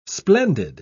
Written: splendid

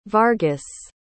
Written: vargas

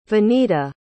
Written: vanida

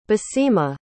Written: baseemah

